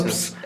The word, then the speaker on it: Phelps: Phelps.